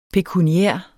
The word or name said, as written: pekuniær